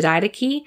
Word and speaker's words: Didache: It's from the Didache, and the Didache is, if you go on Wikipedia, you can look it up, and it's not spelled like how it sounds. It's actually spelled like D-I-D-A-C-H-E, so it's spelled like did ache, but it's pronounced Didache.